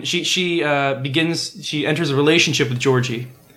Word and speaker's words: George: That's how George is pronounced in Japanese. – I know. It's fun to say it as Georgie. – That's true. – That is Georgie. – It's more like Joji.